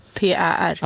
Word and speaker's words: Kuiper: Nej, det uttalas i alla fall koi som fisken japansk. – Kuiper.